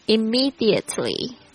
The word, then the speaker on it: Immediately: Toilet T-O-I-L-E-T Toilet 是指洗手间坐便器。 Repair R-E-P-A-I-R 就是修理。 Immediately 或者是 Immediately 这个呢这个 T 呢通常就是美式发音呢不会发得非常的重。 Immediately 就是立即马上。 I-M-M-E-D-I-A-T-E-L Why? Immediately. Flush. F-L-U-S-H.